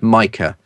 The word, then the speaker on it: Micah: his name is pronounced Micah Hyde. It's Micah, not Mika. It's Micah Hyde."